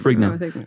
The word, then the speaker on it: Ferrigno: Ferrigno.